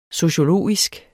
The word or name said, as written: sociologisk